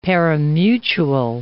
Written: pari-mutuel

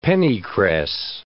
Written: pennycress

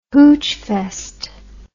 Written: hoochfest

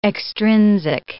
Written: extrinsic

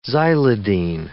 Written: xylidine

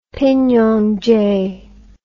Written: pinon jay

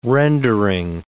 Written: rendering

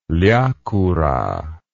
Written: liakoura

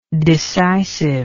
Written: decisiveness

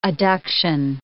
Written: adduction

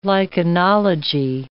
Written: lichenology

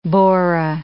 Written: bora

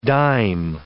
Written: disme

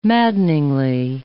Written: maddeningly

